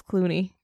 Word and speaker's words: Cluny: C-L-U-N-Y. How do you pronounce that? – Clunny? Clooney? – Thank you. I called it Clunny. Evidently, it's Clooney.